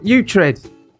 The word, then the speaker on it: Uhtred: How would you pronounce, I mean, I'm asking you this, how would you pronounce U-H-T-R-E-D? – I'd go to a doctor. Uthred. – Uthred. – Uthred. – Uthred. – Oh, Uhtred. Like, Putrid. – No, Uhtred, like off The Last Kingdom on Netflix.